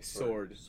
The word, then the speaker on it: sword: You know that the W is silent. – Oh, okay. – Okay, sword.